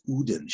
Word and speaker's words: Odin: This girl was doing a virtual reality thing, and I played what I call Odin, the Nordic god, and it's pronounced Odin.